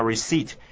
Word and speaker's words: receipt: 再来呢是 Miss Mad, 这个啊气坏的小姐呢，她说的最后一句话，她说 ,Fine, here's my receipt. Receipt 这个字啊，大家呢都很熟悉的字啦，拼法是 R-E-C-E-I-P-T, 对不对？但是在念的时候呢，特别要注意到那个 P 不能念的，我们念作 receipt, 所以呢千万不要念成 receipt, 这样不但不对啊，而且也不好听啊。